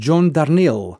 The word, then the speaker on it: Darnielle: Είναι εδώ και πάνω από τρεις δεκαετίες το βασικό όχημα έκφρασης του John Darnielle. Έτσι προφέρεται, μάλλον εγώ τον έλεγα λάθος, αλλά το τσέκαρα. Το Darnielle γράφεται D-A-R-N-E-E-I-E-L-L-E.